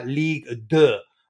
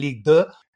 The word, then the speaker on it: Ligue 2: And then they were relegated to Ligue 2, I think is the right pronunciation, Graham.